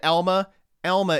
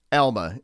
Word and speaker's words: Elma: I'm not going to do a whole P101, but Elma is how that's pronounced. They are very, very insistent that you pronounce it Elma. Just like the folks in Western Massachusetts don't want to hear any H when you talk about Amherst. This is how you pronounce Elma.